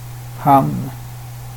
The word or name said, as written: hann